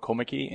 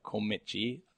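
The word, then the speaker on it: Kommetjie: I was born in a small town called Komeki in Cape Town. It's K-O-M-M-E-T-J-I-E. So it's like Komeki.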